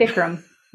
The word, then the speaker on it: Bikram: including Bikram yoga, Bikram. Did I say that right? I don't know if I did. – Bikram. Yep. Hot.